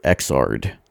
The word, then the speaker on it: Xrd: We're going to listen to Lily from Guilty Gear Xrd. That's XRD, and I'm pretty sure that's how it's pronounced.